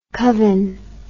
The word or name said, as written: covin